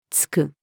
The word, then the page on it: 吐く: つく; female